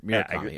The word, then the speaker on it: Murakami: And so did I say his name right? Yeah, Haruki. – Yeah, Murakami. Yeah, I did.